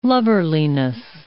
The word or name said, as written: lubberliness